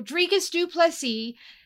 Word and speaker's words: Dricus du Plessis: His last name is with the I and without with the silent S. But you're right. Okay. His first name is with the hard S. So Dricus du Plessis, man, I hate saying this out loud, defeated Robert Whittaker, and he made it look easy.